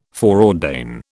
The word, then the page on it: foreordain: British English